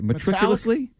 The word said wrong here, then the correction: meticulously: Is it matriculously? Am I saying that right? – Meticulously. – Patio? – Meticulously.